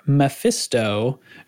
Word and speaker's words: LVLEPHISTO: The Comfy Cannon Cruise is being hosted by Commander Mephisto, and that's spelled LVLEPHISTO. He told me how to pronounce it because the LVL is to look like an M, right?